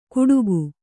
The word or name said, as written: ಗುಡುಗು